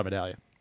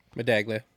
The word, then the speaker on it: Medaglia: And the Medaglia era and Tyler Medaglia. – Medaglia. – I call him Medaglia. – Medaglia. – Silent G. – Like a medallion steak.